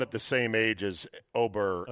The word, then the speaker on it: Åberg: Ludvig Åberg, pronounced Ober.